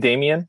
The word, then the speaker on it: Demian: And you say my name, Demian,